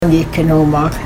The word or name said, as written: n'gii-gkinoohmaag